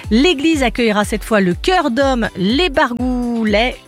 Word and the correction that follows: Barlouguers: alors les Barlouguers, voilà, que je le prononce bien quand même, les Barlouguers, pour une soirée de polyphonie couseranaise.